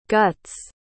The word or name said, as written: guts